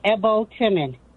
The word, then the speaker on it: Ebhotemen: Last name is Ebhotemen.